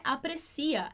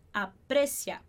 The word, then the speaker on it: aprecia: Aqui atenção para a pronúncia, não é aprecia, é te aprecia. A gente põe a ênfase no E, aprecia, perfeito?